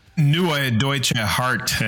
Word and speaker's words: Neue Deutsche Härte: It's it is the essence of the genre Neue Deutsche Härte. Did I pronounce that right? – Yeah, that's correct. Yep. Neue Deutsche Härte.